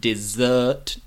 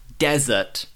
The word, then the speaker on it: dessert: Here, pronunciation, dessert, dessert, like a cake, for example. Not desert, like the Sahara, the Atacama, the Gobi Desert. No, not desert, dessert.